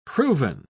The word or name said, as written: proven